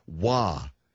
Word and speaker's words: Roy: You got a great goalie in Patrick Waugh, and it's spelled R-O-Y.